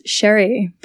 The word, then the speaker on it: Xri: Um, it's from Malta, so my parents are both um, from Malta, and in their language, X is pronounced as pronounced as like a sh sound. – Ooh, very um, cool. So yeah, it's Sherry, but I think I've had every pronunciation under the sun to try and get that right.